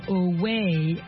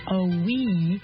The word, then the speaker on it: owe: The word of the day, oh, ça s'appelle O-W-E, et contrairement à la façon dont ça s'écrit O-W-E, O-W-E, on penserait peut-être dire away or oh-we, non, ça se dit oh. Et en fait, ça s'entend comme si si on parlait de la lettre O, oh, ou l'exclamation oh, oh.